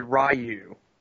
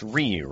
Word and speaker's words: Ryu: So USA back in the 90s when they had a Street Fighter animated show, the guy who voiced Ken, he always said Ryu. – Oh. – Of course, because he's a lazy American. – Yeah, it was just that is not how you pronounce his name, and that's what they did the entire series. – It's Ryu, right? – Yeah. – Okay. Some people say Rue, and I'm like, what? – Oh, that's interesting.